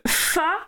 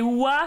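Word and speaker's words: Wa (花): Donc, du coup, Fa, c'est la prononciation cotonnée du nom de famille de Mulan. Et Wa, c'est la prononciation correcte en mandarin qui signifie fleur.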